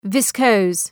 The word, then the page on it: viscose: ‘vıskəʋs